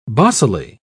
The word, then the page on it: bossily: British English